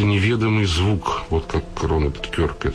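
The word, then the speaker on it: Kirk: Вот Рональд Кирк, которого, ой, извините, Кёрк, он правильно произносится, который, я привык его Кирк произносить,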